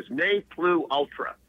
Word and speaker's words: ne plus ultra: N-E, there's the first part, followed by what looks like the letter or the word plus, P-L-U-S, pronounced plu, I believe, and ultra, U-L-T-R-A.